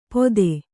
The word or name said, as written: ಪೊತ್ತೆ